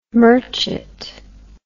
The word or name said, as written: merchet